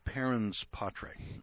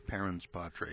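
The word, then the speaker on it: parens patriae: Parens patre. I write this down because it's a Latin term. And it says P-A-R-E-N-S. That's the first word. P-A-R-E-N-S. Parens patre. And patre is spelled P-A-T-R-I-A-E. It's an odd spelling. P-A-T-R-I-A-E.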